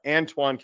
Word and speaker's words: Antoine: I wanted to make sure the pronunciation. So I got Antoine Kelly told me, and I was like, oh, you're pitching today?